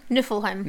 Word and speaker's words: Niflheim: How do you say their name? Niflheim? – Niflheim.